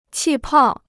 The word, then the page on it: 气泡: qì pào